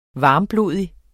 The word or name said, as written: varmblodig